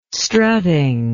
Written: strutting

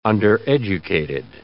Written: undereducated

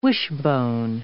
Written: wishbone

